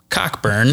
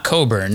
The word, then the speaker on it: Cockburn: It, it, it's pronounced Cockburn. it is not Coburn.